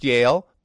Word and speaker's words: Joehl: His name, by the way, may be pronounced Yale, but it is spelled J-O-E-H-L.